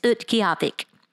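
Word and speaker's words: Utqiaġvik: Now, I will tell you, Barrow changed their name a couple of years ago, and I practiced a whole lot on how to say it, and it's Utqiagvik.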